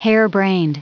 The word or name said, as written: harebrained